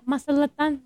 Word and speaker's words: Mazatlán: Mazatlán. – Mazatlán.